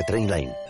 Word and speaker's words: Trainline: Se pronuncia Trainline.